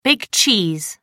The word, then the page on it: big cheese: American English